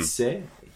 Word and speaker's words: sait: Now, "fête" is uh, F E circumflex T E, and "sait" he knows the T is silent. In fet, the T mm-hmm. is not silent. And so the E is longer.